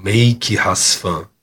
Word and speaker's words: Mêike Rás Fân: Então, Make Rasfan. Terminei grafando é, colocando os acentos de uma forma que não ficasse é, é, dependendo da, da, da, língua e da pronúncia. Então ficou make, com acento circunflexo, has, fan.